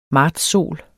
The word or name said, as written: martssol